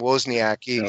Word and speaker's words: Wozniacki: And Caroline Wozniacki, by the way, that's how you pronounce her last name, Wozniacki.